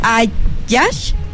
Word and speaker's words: Hajaj: Es Ayash, Ayash. O sea, H-A-J-A-J. Ayash. O sea, es como ja, ja, ja, pero ajá.